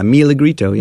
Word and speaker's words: Milagrito: Is that how you say it? – Yeah, Milagrito. Yeah. – Milagrito. – Milagrito is a Spanish word that means little miracle.